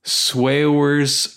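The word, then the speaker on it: Saoirse: Swears Ronan. – Okay. I think you're pronouncing it exactly right.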